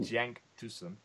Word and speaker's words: Cenk Tosun: Keep an eye out, by the way, because I did an interview with a Turkish football expert on Cenk Tosun.